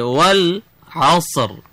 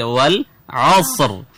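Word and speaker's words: والعصر: ওয়াল আসর এখানে আইন আছে আইনটা হলকের মাঝখান মানে আমি মাখরাজ নিয়ে আলোচনা আইনটা হলকের মাঝখান থেকে মানে গলার মাঝখান থেকে উচ্চারণ করতে হবে বলো কোথা থেকে উচ্চারণ করতে হবে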